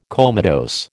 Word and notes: comatose: American English

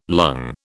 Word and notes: lung: British English

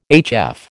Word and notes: HF: American English

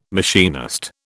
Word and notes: machinist: British English

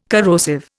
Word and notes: corrosive: Indian

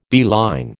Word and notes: beeline: American English

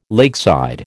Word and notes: lakeside: American English

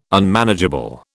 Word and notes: Unmanageable: British English

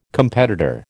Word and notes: competitor: American English

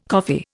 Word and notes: coffee: Indian

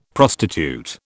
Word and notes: prostitute: British English

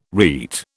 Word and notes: rete: British English

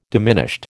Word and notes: diminished: American English

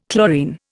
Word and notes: chlorine: Indian

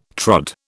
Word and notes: trod: British English

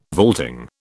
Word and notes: vaulting: British English

